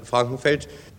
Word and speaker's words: Frankenfeld: Mein Name ist Frankenfeld, also es wird immer falsch ausgesprochen, wie Krankengeld, nur nicht so dick.